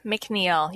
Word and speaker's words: McNiel: Well, my handle is my first and last name almost everywhere, Catherine McNeil.